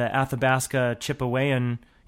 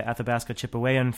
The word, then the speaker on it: Athabasca Chipewyan: And then Leonardo DiCaprio and members of the Athabasca Chipewyan, yeah, that's how you say it, Athabasca Chipewyan First Nation up in Canada,